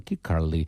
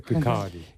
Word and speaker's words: Kirkcaldy: pronunciado en Kirkcaldy, en Escocia, en 2005, el discurso de Greenspan. – Se pronuncia Kirkcaldy. – Kirkcaldy. Kirkcaldy.